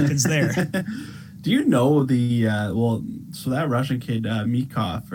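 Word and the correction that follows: Michkov: I think it's Mishkov, Matvey Mishkov. – Oh, Mishkov.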